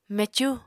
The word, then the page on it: mecú: Apatani